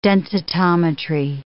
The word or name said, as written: densitometry